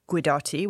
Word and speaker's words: Guidotti: So we put our minds together and, and came up with Belladotti, which is a combination between our name, which is Guidotti,